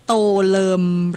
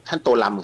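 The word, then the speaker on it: Tô Lâm: ค่ะถามเรื่องการออกเสียงชื่อท่านหน่อยได้ไหมคะอ่านว่าโตเลิมหรือโตลัมคะโตลัมครับผมอยู่ที่เวียดนามเรียกท่านโตลัมครับ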